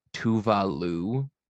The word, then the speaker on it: Tove Lo: But something that came up on a recent episode that I watched that I thought was fascinating and didn't know is that, did you know that the Swedish singer Tove Lo, her name is Tuva Lu? – Ew. – Yeah, that's how they say it in Sweden, I guess.